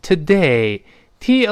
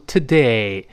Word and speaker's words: today: 那个呃的今天读成 today，t o 里那个 o 呢发啊，所以是 t t 后面重读 today。